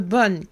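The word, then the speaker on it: Bön: Alors, le terme bonne, qu'on prononce bonne en fait, hein, mais ça s'écrit bonne, B-O-N, avec un tréma sur le O, euh, mais ça se prononce bonne, donc je vais dire bonne, désigne les traditions religieuses tibétaines distinctes.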